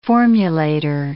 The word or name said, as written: formulator